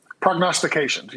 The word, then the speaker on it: prognostications: Prognostications. I think I might have said it right. – Prognostications. Yes, you did.